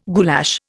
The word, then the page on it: goulash: Indian